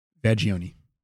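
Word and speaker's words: Vaggione: I don't know if that's the right pronunciation to put on that word, but it's V-A-G-G-I-O-N-E. Vagioni.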